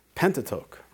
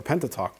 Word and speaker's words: Pentateuch: And again, Genesis is the first book of the Pentateuch, right? Or Pentateuch, depending on how you want to pronounce it.